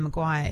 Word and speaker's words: McGuire: You know, my surname, Maguire, is spelt MC, big M, little C, big G, U-I-R-E, right? And obviously it gets misspelled all the time, blah, blah, blah. But it's not originally spelt M-C-G-U-I-R-E. Oh. It was originally M-A-G-U-I-R-E.